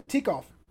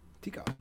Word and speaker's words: Tikov: The ship is the Tikhoff. – The Tikhoff. – T-I-K-O-V. Tikhoff.